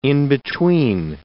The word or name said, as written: in-between